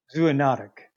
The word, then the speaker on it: zoonotic: And I know I'm going to mangle this terminology, zootonic. Is that the correct way to say? – Zoonotic, I think it's okay. Pronounced. So like a zoo, from the zoo.